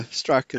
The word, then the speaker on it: Strzok: Well, and so, I can tell you that this idiot that was Strzok, whatever, you know, however you say his name. – Yeah, Strzok is right.